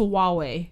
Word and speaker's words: Huawei: For the longest time, you told me that your phone was pronounced Huai. That's what you told me. Like, oh yeah, my phone. I have the Huai. It's definitely pronounced Huawei.